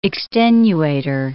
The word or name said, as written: extenuator